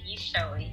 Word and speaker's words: Gichohi: Gichohi. I am Kenyan, so it's hard to spell and pronounce, but Victoria Gichohi, yes.